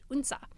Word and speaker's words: Odense: I'm going to Denmark in August, so I can't say it's my favorite yet because I haven't been, but I'm very excited to go to Odense, which I have learned is pronounced Unsa.